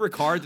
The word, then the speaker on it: Ricard: Is it Ricard? Did I say that right? Well, or is it something else? – You did.